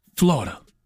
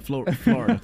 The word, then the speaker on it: Florida: Florida's three syllables.